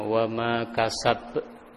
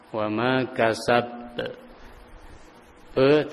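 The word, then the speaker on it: wama kasab (كسب): Wama kasab be, itu kesalahan dalam pengucapan qalqalah. Harusnya tidak perlu dikasih hamzah.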